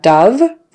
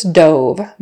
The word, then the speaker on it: dove: So we have dove versus dove, both spelled D-O-V-E. Dove, short U, kind of a schwa. The dove flew past my window, meaning the white bird, the dove, the bird of peace. Dove, long O, dove is the past tense of dive.